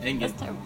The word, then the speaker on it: Engin: Engin. – Engin. Yeah. How do you spell that? – E-N-G-I-N. – E-N-G-I-N.